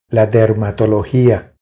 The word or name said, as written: La dermatologia